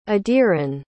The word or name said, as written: adiran